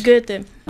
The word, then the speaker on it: Goethe: Goethe.